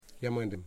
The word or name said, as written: Yamandim